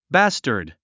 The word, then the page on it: bastard: bǽstərd; バスタード